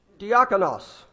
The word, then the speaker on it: diakonos: Diakonos. Did I say that right, David? Satisfied you. My Greek scholar is happy.